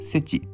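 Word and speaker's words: SETI: まあ、それの英語の頭文字を取って、世知と読みます。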